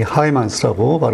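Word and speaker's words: Heymans: 이 하이만스 이제 그 벨기에 사람이라 영어로 스펠링은 H E Y M A N S인데 예, 하이만스라고 발음을 하는 것 같아요.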